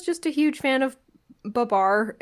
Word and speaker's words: Babar: And one of them is a Babar stuffed elephant. Or Babar, depending on your preferred pronunciation.